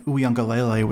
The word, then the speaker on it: Uiagalelei: When you watched him in those few games without Lawrence, when Uiagalelei was the starter, he looked mediocre.